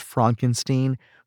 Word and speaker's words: Frankenstein: It's pronounced Frankenstein, who follows in his father's footsteps and creates a monster of his own.